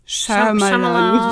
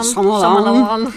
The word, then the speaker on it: Shyamalan: Night Shyamalan. Shyamalan.